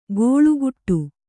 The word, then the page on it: ಗೋಳುಗುಟ್ಟು: gōḷuguṭṭu